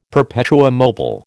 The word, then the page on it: perpetuum mobile: American English